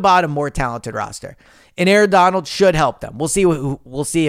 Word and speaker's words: Aaron Donald: Aaron Donald, I don't know why I mispronounced that. Aaron Donald, out of retirement, official on Sunday.